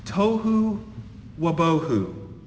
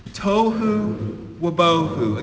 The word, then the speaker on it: tohu wabohu: These two words are pronounced tohu wabohu. Say it with me on the count of three. One, two, three. Tohu wabohu.